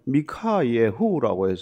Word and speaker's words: 미카예후: 근데 미가라는 이름의 히브리식 발음은 미카예후라고 해서 누가 여호와 같은가, 그런 이름인데 이렇게 줄여서 이제 미가라고 되어 있어요.